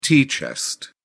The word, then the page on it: tea chest: British English